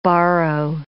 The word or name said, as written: borrow